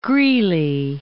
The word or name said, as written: Greely